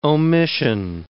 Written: omission

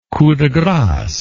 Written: coup de grace

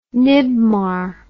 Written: NIBMAR

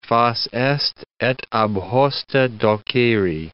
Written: fas est et ab hoste doceri

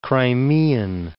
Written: Crimean

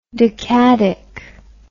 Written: decadic

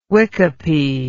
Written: wicopy

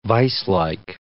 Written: viselike

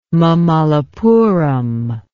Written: mamallapuram

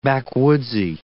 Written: backwoodsy

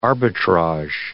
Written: arbitrage

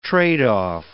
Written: trade-off

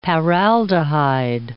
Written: paraldehyde

